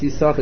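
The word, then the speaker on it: Yissachar: Throughout the whole Tanakh, it's pronounced Yisachar as if there were only one. It's spelled with two shins. They pronounce it as if there were only one sin, as if there were only one shin.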